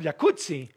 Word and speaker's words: jacuzzi: Entonces deberíamos pronunciarlo jacuzzi. – Exacto. – Como pizza. De... como Rampazzo, sí. Como Claudia Rampazzo.